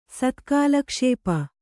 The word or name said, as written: ಸತ್ಕಾಲಕ್ಷೇಪ